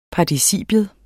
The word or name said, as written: participium